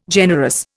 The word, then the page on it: generous: Indian